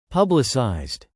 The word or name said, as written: publicized